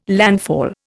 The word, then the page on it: landfall: Indian